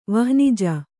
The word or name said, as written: ವಹ್ನಿಜ